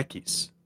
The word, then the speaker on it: Equis: The emphasis was on the first syllable there. Gotta let you know. Equis.